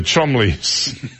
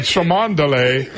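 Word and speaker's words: Cholmondeley: In Touch claims that despite William's denials, Kate ordered William to stop socializing with the Chumleys. It looks like Chumondeley. – Right. – It's pronounced Chumley.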